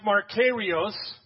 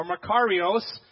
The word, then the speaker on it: makarios: And in the Greek, it's pronounced Makarios or Makarios, however you want to say it. If you're from Oklahoma, it's one way. If you're not, it's from another.